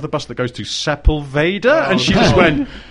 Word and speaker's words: Sepulveda: I know it's Sepulveda.